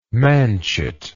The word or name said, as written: manchet